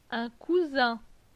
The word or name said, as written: Un cousin